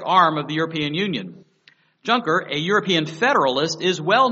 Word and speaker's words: Juncker: We read the following, quote, European leaders in Brussels have nominated Juncker, J-U-N-C-K-E-R. I may be pronounced different in French, but J-U-N-C-K-E-R, Juncker.